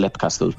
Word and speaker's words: Leap Castle: pero como Sean Ryan lo pronuncia Leap Castle, yo me quedé con Leap Castle.